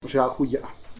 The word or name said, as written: biagxagulla